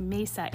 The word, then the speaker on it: Mesa: I know, it looks like Mesa. It's pronounced Mesa, Arizona.